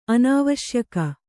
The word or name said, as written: ಅನಾವಶ್ಯಕ